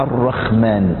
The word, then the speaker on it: الرحمن: الرحمن، كلمة الرحمن عرفت في الأديان السابقة، لكن العبرانيين أو اليهود كانوا ينطقونها الرحمن، وما زال الآن تنطق بالخاء لا بالحاء، لكنها في العربية بلسان العرب بالحاء.